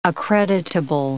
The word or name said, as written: accreditable